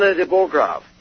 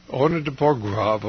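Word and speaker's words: Arnaud de Borchgrave: Arno de Borgrave? How do you pronounce it? – Arno de Borgrave. – Arno de Borgrave, okay.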